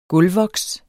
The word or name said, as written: gulvvoks